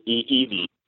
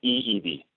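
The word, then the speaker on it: i'iwi (ʻiʻiwi): So the eyes are pronounced like a e sound, and the e's are pronounced like a. So for that particular bird, it's pronounced i'iwi. – E-E-V, all right. – With the W sounding like a V sound.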